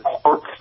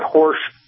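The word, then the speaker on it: Horch: It's got this was a pretty Audi car, and many people pronounce it Horsch. Some people, of course, call it Horsch, rhyming with Porsche.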